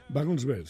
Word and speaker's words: Wagensberg: Wagensberg, creo que lo he dicho bien, pero un profesor tuyo un día te hizo la broma de Wagensberg. – Wagensberg, sí, no un día, no, solía...